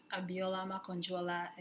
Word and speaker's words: Abiola Makanjuola: Also, we're on Facebook and it's at Abiola Makanjuola.